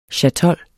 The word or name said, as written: chatol